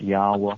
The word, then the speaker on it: Yahweh: It's pronounced Yahweh. It's like when you breathe.